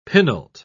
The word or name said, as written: penult